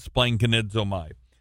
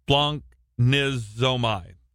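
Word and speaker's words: splanknizomai (σπλαγχνίζομαι): I always like to pronounce it, and it's wrong according to the audio version of my study program, but I pronounced it splankanidzomai. But it splanknizomai. That's so it doesn't have the z on it.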